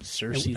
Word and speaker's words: Cersei: Cersei, is that how you say it? – Yep, Cersei and Lannister.